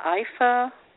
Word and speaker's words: Ifa: Is it Ifa, Ifa? – The way I learned to pronounce it is Ifa.